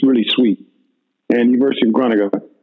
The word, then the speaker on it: Groningen: And it's spelled Groningen. They pronounce it Groningen.